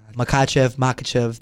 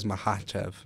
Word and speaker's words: Makhachev: Versus number two, pound for pound, Islam Makhachev. They always say it. I don't even know. Actually. – I pronounce it the John Anikwe. He knows. He says Makhachev.